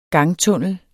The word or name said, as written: GANGTUNNEL